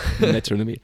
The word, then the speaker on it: Metronomy: Und zwar The Look from Metronomy. Richtig ausgesprochen, Nick? – Ja. – Metronomy.